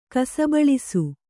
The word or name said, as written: ಕಸಬಳಿಸು